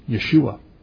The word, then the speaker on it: Yeshua: See, Joshua is how we pronounce it in English, but in Hebrew it's Yeshua.